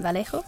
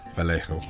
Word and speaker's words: Vallejo: How would you say that? – Vallejo. – Vallejo, I knew you would.